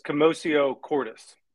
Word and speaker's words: commotio cordis: i believe it's commotio cordis commotio cordis it,